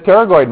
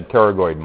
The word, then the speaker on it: pterygoid: The pterygoid muscles. That's right. So don't forget that P is not pronounced. It's silent. Start pronouncing it with a T, right? The pterygoid muscles right there.